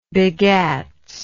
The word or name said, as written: begats